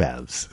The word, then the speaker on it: halves: Two halves. There's a silent L in there.